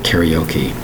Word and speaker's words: karaoke: Think of that word karaoke. Three syllables. That look at the spelling. K-A-R-A-O-K-E. Now we're talking four syllables. Karaoke. Notice how he's pronounced that. Karaoke. K-A-R-A-O-K-E.